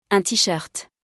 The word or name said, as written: un t-shirt